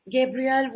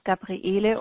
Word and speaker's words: Gabriele: Yeah, it's the German version with E-L-E at the end. So it's in German, it's Gabriele, or in English, it's Gabrielle. It's not okay. – Gabriel, it's the male version. It doesn't have the second E at the end.